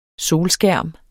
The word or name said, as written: solskærm